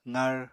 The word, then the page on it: qar: apatani